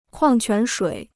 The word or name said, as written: 矿泉水